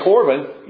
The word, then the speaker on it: Corban: If you can say Corban, you pronounce the Hebrew word.